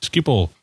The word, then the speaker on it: Schiphol: Yeah, didn't take them up on the opportunity to explain to me how to pronounce Schiphol. – Yes, Schiphol. – Okay. – Did you put lots of O's in it and use lots of phlegm? – Ah.